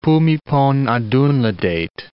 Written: Bhumibol Adulyadej

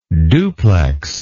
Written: duplexity

